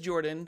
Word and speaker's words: Jorphdan: My name is Jordan, with a silent PH in the middle, and I am joined always by my wonderful co-host, Sir Lucian, over at Sir Lucian Gaming.